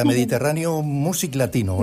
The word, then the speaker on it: Mediterráneo Music Latino: Déjame mandar un saludo a Alfred y a Mediterráneo Music Latino. ¿Lo he dicho bien? – Sí, lo has dicho muy bien.